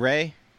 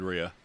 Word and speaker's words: Rhea: Am I the crazy one who pronounced it as Rhea of the Coos and not Ray? – I always said Rhea. – I did too. – But it's probably Ray. – It is. – I'm sure it is Ray.